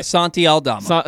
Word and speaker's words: Santi Aldama: This week, the guys caught up with Grizzlies rookie and former Loyola star Santi Aldama. I believe I said that correctly. – Santi Aldama.